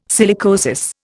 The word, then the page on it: silicosis: Indian